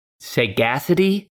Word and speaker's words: sagacity: Okay, so I did pronounce it right. It is sagacity.